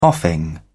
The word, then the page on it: offing: British English